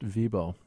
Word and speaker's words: Wibaut: His name is Frank Vibo, W-I-B-A-U-T, but pronounced Vibo.